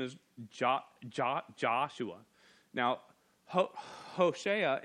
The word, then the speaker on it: Hoshea: One is from the tribe of Judah, Caleb, son of Jephunneh, and from the tribe of Ephraim, and I've heard it pronounced a few ways, it's Hoshea, the son of Nun.